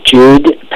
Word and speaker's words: Jude: Okay, my name is Jude Patton. It's spelled J-U-D-E. Last name is P-A-T-T-O-N.